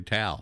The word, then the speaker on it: Tow: Yeah, like there's a place called Tow, Texas. Did you know that? – Well, it's actually Tow.